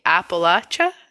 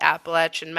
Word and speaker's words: Appalachia: So, and I wanted to point out here that Flatwoods is deep in the hills of the Appalachia. Is that how you say it? – I say Appalachia because I'm a northerner, but I think the locals say Appalachia.